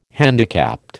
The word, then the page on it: handicapped: American English